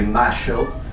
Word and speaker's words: Mayo: So the 25 de Mayo, or as Argentines or Porteños, those from Buenos Aires, those of the port pronounce it, 25 de Mayo. They do that weird thing with the Y.